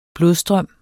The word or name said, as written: blodstrøm